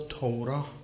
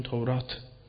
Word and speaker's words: تورات: کلمه توراه که می دانیم واژه یهودی است، لذا توراه تلفظ نمی کنیم. مثل رحمت نیست که رحمه، مثل شریعت نیست که شریعه به های آخرش سکت کنیم. نه، این توراه غلطه، همون تورات به ته باید وقف بشه و ساکن بشه.